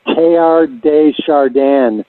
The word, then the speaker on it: Teilhard de Chardin: His name is pronounced Teilhard de Chardin.